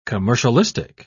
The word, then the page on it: commercialistic: British English